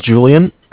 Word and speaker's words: Julian: My name is Julian. It's spelled J-U-L-I-A-N.